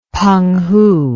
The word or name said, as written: Penghu